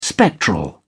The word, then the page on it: spectral: British English